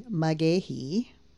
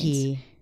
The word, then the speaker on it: McGaughey: Magehi. – Mm. – There's a lot of Irish running through these names. – Magehi. – Sharon K. Magehi. – How do you spell it? – M-C-G-A-U-G-H-E-Y.